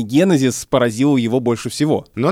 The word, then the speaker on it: генезис: Смотри, после ударного слога три безударных. Это жутко неудобно для языка. И поэтому, как бы мы этому не противились, а язык толкает нас в сторону ударения генезис.